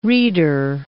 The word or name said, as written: reader